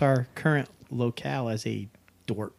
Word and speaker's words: dorp: Dwerp. – Dwerp. – Yeah. A small rural town or village. Often used to suggest a place that is backwards or unimpressive. – How do you spell that? – D-O-R-P.